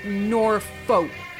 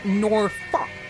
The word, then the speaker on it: Norfolk: Well, see, I really want to say Norfolk, because that's how it's spelled, but the Brits say Norfolk, which to me is difficult.